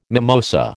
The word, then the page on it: mimosa: American English